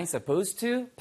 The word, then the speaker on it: supposed to: 근데 빨리 들으면 am I supposed to? 발음은 ed가 없는 것처럼 들립니다.